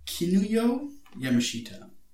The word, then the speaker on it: Kinuyo Yamashita: Kinuyo Yamashita.